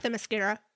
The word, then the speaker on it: Themyscira: Themyscira.